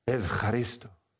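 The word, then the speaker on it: Evcharisto (ευχαριστώ): And he says, Evcharisto. And I went, oh, I learned that in seminary. Evcharisto, thank you. They changed the ou to ve in modern Greek.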